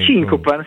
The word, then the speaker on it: Cincopan: Ho detto bene Cincopan come... – Cincopan, come... sì, hai detto bene.